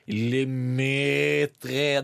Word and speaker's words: Le Maître: Man uttaler det Le Maître. Det er litt som måten man uttaler Sven på.